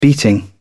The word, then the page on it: beating: British English